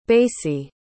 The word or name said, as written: basie